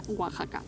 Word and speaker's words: Oaxaca: pero no se escribe así, por si alguno tenéis interés en buscarlo. Se escribe como Oax. Oax. pero se pronuncia Oaxaca.